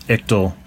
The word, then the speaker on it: ictal: Anyway, the authors of this particular study are using the terms ICTL, or Ictal, I guess some people pronounce it, but we're going to go with Ictal today.